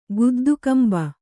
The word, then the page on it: ಗುದ್ದುಕಂಬ: guddu kamba